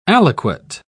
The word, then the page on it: aliquot: British English